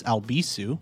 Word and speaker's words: Albisu: And if you'd like to follow me, you can find me on social media at Alex Albisu.